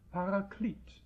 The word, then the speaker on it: Paraklet: Und dort wird es, natürlich mit hebräischen Buchstaben, Paraklet geschrieben und auch so ausgesprochen.